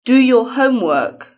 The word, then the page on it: do your homework: British English